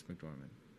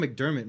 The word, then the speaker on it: McDormand: Francis McDormand. Oh, not McDermott. McDormand. – Oh, McDormand.